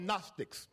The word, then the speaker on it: Gnostics: These group of people were called Gnostics. The G is silent. Somebody say Gnostics.